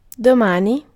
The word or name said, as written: demain